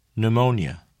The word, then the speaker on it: pneumonia: The letter P is silent in the following words. Repeat them during the pauses. – Receipt. Psychology. Pneumonia.